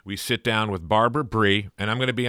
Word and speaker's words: Bry: I called her Bri for many years. I have a theory, which is the actual pronunciation is Bri, but it's been changed to Bree because Bri rhymes with lie, and in the political climate, you can't have a gimme like that.